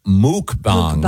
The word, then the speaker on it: mukbang: No, the word, and it's pronounced mukbang. – Mukbangs. – Mukbang. – Mukbang. – Yeah. It's actually pronounced muk, yeah. – Is it muk? – Mukbang. – Yep. Oh, I thought it was mukbang. – The pronouncer here is M-O-O-K. – Oh, okay. I've always heard muk. The people who do it, I've heard say muk. – It's spelled M-U-K-B-A-N-G.